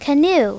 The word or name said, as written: canoe